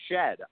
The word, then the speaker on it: Sheard: I believe it's actually Shed. I believe the R is silent.